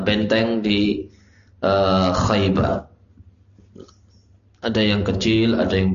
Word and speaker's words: Khaybar: Khaybar cara bacanya seperti Ja'far. Ja'far Khaybar.